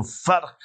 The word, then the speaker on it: vark: So now, and you must be careful how you pronounce this, because you need to know Afrikaans. So they wrote there another V-A-R-K. Then they wrote U-P. So Fark. Fark means the pig. So another Fark up. Fark up. You see that? V-A-R-K.